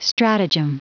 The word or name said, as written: stratagem